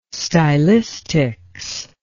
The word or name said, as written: stylistics